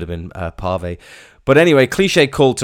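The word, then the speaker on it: Cliché: I've just remembered something, the little accent above the E in Cliche there. That is what tells you to pronounce it Cliche and not Clitch or Clitchy.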